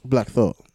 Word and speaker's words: Black Thought: Black Thought. – Thought as in I was thinking. Like thinking. – Yeah. – Okay. Yeah. The way you're pronouncing it was almost like Black Thought.